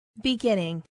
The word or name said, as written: beginning